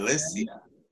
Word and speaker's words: Alicia: Alicia.